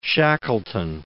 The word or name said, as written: Shackleton